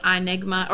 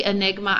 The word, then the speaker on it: iNigma: And I wanted to share my favorite QR scanner, which I actually stole from Tony Vincent, is iNigma, or it's really Enigma. I don't know why I always pronounce it iNigma when I'm saying the app, but I-N-I-G-M-A, because it has no ads and it's free.